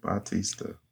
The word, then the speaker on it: Bautista: Did I get his name right? – Yes. Mm-hmm. Yeah, you did. – Yeah. – Heck yeah, you did. – Bautista.